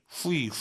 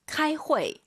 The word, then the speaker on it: 开会: 후이, 후이 하지 마세요. UI 사이에 에 넣으셔야죠. 우리 왕 선생님처럼 开会 이렇게 발음하시고요. 회의하다 중국어로 开会.